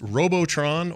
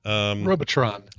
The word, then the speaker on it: Robotron: Ibita Bloke says, is it pronounced Robotron or Robot Ron? Because dogs with brains really wanted to know, he says. – Robotron. It's neither. It's Robotron.